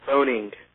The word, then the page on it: phoning: American English